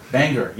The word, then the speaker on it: Bangor: Bangor, yes.